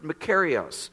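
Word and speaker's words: makarios (μακάριος): The Greek word that's used there is pronounced makarios, and it refers to the highest type of well-being possible for human beings.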